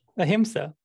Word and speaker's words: Ahimsa: The Lord is Anissa. Correct me if I butchered that word. – Ahimsa. The Lord is Ahimsa.